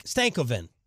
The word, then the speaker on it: Stankoven: He wrote stank as in crank, and I've read it's too slow. Stankoven.